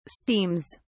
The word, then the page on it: steams: British English